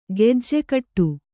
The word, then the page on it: ಗಿಜಿಗುಟ್ಟು: Kannada